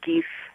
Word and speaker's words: Gift: And I believe it's pronounced in Dutch Gief Cafe.